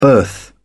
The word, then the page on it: birth: British English